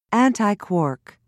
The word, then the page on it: antiquark: British English